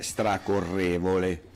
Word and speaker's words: stracorrevole: con l'accento sulla E, stracorrevole, ok? – Stracorrevole.